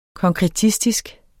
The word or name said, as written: konkretistisk